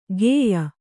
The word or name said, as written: ಖೇಯ